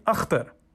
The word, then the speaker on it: Akhtar: "So Ak, like octopus?" – "That works." – "But is that how you say it? Where you're from? Where are you from?" – "Pakistan." – "Pakistan. – And we pronounce the name differently there." – "I'm talented. I can say it right." – "So we say Akhtar." – Father reverted to the native KH guttural sound that no white American in his experience had ever been able to master.